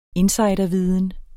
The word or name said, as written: insiderviden